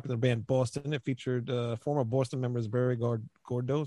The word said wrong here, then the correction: Goudreau: Goudreau On guitars and Brad Delp on backing vocals, as well as future Boston lead vocalist Fran Cosmo.